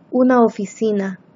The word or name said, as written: una oficina